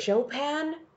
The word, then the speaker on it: Chopin: Chopin? – Yeah, Chopin.